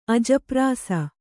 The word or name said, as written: ಅಜಪ್ರಾಸ